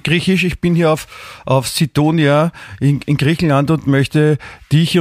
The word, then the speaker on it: Sidonia: Sidonia wird das ausgesprochen, okay. – Sidonia, ja.